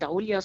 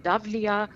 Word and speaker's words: Daulia: Also ich habe mich sehr reingekniet, um zu wissen, wo ist Korinth, wo ist Delphi, wie spricht man Daulia aus, nämlich nicht Daulia, sondern Davlia.